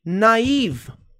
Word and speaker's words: naive: Naive. Naive, que palavra estranha. Essa aqui é a pronúncia dessa palavra, naive.